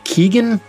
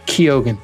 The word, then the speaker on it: Keoghan: It stars Barry Keoghan, which I heard someone else pronounce this on a podcast today. I thought it was Barry Keegan. That was just like, I looked at it and I was like, ah, Keoghan. That is how they pronounced it.